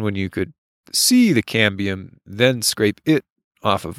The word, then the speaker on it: cambium: A cadmium? – The cambium? – Cambium, I believe. – Yeah. – I'm terrible at pronouncing words, but I think cambium is the correct pronunciation.